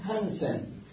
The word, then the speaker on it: هانسن: حالا اینجا میگیم هانسن، این تلفظش درسته، درسته آقا؟ به نام هانسن